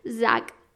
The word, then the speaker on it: Zac: Y él me dijo, pues te voy a enseñar cómo se dice su nombre por si algún día le conoces, que al menos sepas pronunciarlo. Y es Zack.